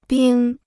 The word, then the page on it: 冰: bīng